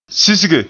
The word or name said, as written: sisge